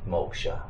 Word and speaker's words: moksha: So that's what Hinduism calls moksha. – Moksha. How do you say that? – M-O-K-S-H-A. Yeah.